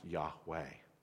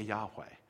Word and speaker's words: Yahweh: We think it's pronounced Yahweh in the early days.